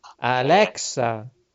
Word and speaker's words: Alexa: non Alessia, Alexa. – Eh sì, mi sbaglio sempre, Alexa.